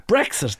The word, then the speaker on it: Brexit: It's Brexit. It's not Brexit. What's this eggs business? It's an X. It's like exit, going out the door.